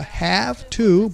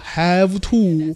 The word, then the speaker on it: have to: Number one，第一个语言点，All I have to say，这个地方注意一下，那个 have to 不要读作 have to，咚咚。 that. We say have to. It sounds like h a f t a.